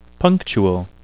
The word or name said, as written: punctual